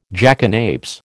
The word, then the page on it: jackanapes: American English